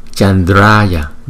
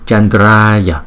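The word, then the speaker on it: Candrāya: Chữ A có dấu gạch ngang, chúng ta đọc hai nhịp. Chandraya, Dara, Chandraya, Chandraya, Chandraya, Chandraya, Chandraya, Ratna, Chandraya, Ratna, Chandraya.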